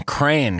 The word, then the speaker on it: Crayon: So proud Northeastern Midwesterner and lifelong Crayon guy. – Yeah. – The one syllable pronunciation is officially accepted by Merriam-Webster, so the science is settled.